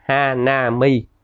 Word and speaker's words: Hanami: Tôi có một thương hiệu gọi là Hanami, đọc là Hanami.